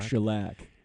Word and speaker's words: Shellac: and it was Shellac, is that how you say it? – Yeah, Shellac.